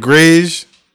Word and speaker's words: Greige: It's called Gray's Shop. Uh-huh. That's spelled Greige.